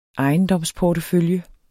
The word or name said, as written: ejendomsportefølje